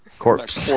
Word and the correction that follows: corps: Not corpse. Not corpse. They're not looking for dead bodies. The core.